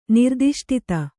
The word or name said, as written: ನಿರ್ದಿಷ್ಟತೆ